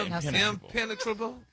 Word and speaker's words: impenetrable: No, your emphasis is on the wrong syllable. – They were so dumb. They had face masks. Not a chance. – Impenetrable. – Impenetrable. – Yeah. – Impenetrable. – There you go.